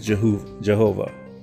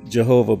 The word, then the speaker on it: Jehovah: However, there are three recognized pronunciation and spelling which are one Yahweh, which is Y A H W E H. The second is Y A H A V E and the third is Jehovah. The, for, the preferred pronunciation is that most people use is Jehovah, but everyone else in the Hebrew tongue will say Yahweh.